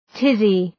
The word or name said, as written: tizzy